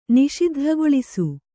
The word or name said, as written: ನಿಷಿದ್ಧಗೊಳಿಸು